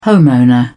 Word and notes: homeowner: British English

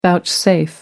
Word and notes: vouchsafe: American English

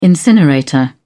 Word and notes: incinerator: British English

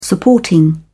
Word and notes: supporting: British English

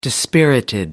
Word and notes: dispirited: American English

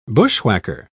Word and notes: bushwhacker: British English

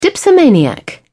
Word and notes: dipsomaniac: British English

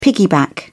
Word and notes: piggyback: British English

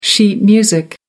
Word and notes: sheet music: American English

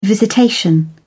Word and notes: visitation: British English

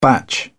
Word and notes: batch: British English

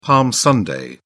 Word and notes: palm sunday: British English

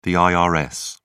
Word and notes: irs: British English

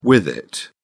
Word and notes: with-it: British English

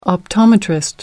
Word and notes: optometrist: American English

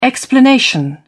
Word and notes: explanation: British English

